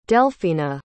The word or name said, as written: delfina